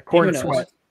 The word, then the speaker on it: Corenswet: Superman Legacy finds leads with David Corenswet. Did I pronounce that right? – Yeah, Corenswet.